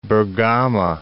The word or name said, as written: Bergama